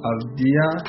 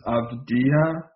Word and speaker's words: Avdija: We got LaMelo Ball, who's been playing over in Australia, and Denny Avdija, I believe is the correct pronunciation. It's like a soft J.